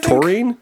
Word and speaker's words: taurine: How is that chemical pronounced? – Taurine, I think. – Taurine, yeah, because I had bought a energy drink.